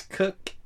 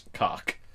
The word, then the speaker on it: Koch: Yeah, they're the same assholes that would pronounce K-O-C-H as cook and not cock.